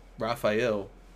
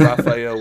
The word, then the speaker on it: Rafael: Rafael, not a silent R or whatever you want to say. I'm going with Rafael with the R.